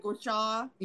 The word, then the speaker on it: Gua Sha: I definitely noticed a difference when I started using, and I can never say the name, the Gua Sha. Am I saying that right? – Oh, yeah, yeah, yeah. – That Gua Sha.